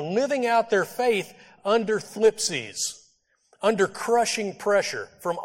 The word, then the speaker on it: thlipsis: The Greek word used here is thlipsis. It's spelled T-H-L-I-P-S-I-S, thlipsis.